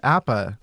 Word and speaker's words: Apa: How do you say his name? Apa.